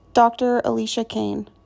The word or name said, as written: Dr. Alicia Kane